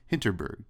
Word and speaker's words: Hinterberg: Uh, so yeah, that's Dungeons of Hinter, Hinton Hinterberg. Is that how you pronounce it? Hinterberg, Dungeons of Hinterberg.